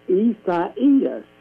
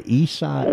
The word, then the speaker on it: Isaias: Isaias is how you say it. – Okay, Isaias. – And it is the Spanish and Portuguese version of Isaiah.